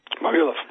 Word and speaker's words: Vavilov: Vavilov.